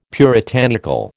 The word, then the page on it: puritanical: American English